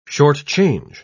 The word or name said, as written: shortchange